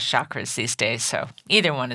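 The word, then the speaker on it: chakra: Is it chakras or chakras? The proper way to pronounce it is chakras, like the hard CH, like church.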